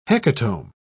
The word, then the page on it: hecatomb: British English